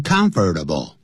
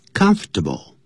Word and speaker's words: comfortable: 但 comfortable 不要念成 comfortable，因为实际上外国人把 fur 那个音省了掉，只念成 comfortable，因为这样比较好念。